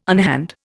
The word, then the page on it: unhand: Indian